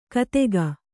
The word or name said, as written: ಕತೆಗ